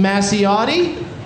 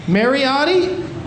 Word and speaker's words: Mariotti: And so that's in the bag tonight with a note that says, the book is signed by CEO of Funko, Brian Masciotti. Is that how you say it? – Mariotti.